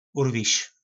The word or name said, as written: urvis